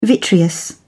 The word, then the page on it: vitreous: British English